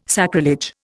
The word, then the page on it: sacrilege: Indian